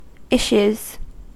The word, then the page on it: issues: American English